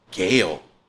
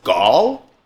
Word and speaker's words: Gaal: And the actress that they got to play, they pronounce it Gail. In the show, I always saw, I always pronounced it in my head as Gaal. It's G-A-A-L.